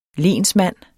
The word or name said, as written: lensmand